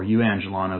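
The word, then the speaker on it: euangelon (εὐαγγέλιον): or euangelon as it would be pronounced in Greek, the gospel itself, the good news, the good tidings.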